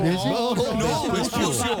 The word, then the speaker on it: Vespucio: ¿Ese American Prepucio? – No, Vespucio. – ¿Cómo se dice? – Vespucio. – Vespucio. – ¿Y yo cómo dije? – No.